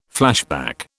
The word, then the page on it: flashback: British English